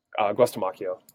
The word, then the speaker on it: Guastamacchio: Guastamacchio.